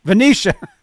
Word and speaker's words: Venetian: Roth performed at the Oracle Cloud World 2023 event at the, is it Venetian? How do you pronounce Venetian. – That? Venetian. Thank you.